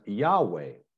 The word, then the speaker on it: Yahweh: Concept is a translation of what's known as the Tetragrammaton, the four letters in the Hebrew scriptures defining the nature of God, YHWH, pronounced by us as Yahweh, but by faithful Jews never pronounced.